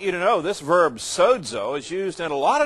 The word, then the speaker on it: sozo: The Greek word behind it is sozo. S-O-Z-O, pronounced as if there's a D in there. Sozo.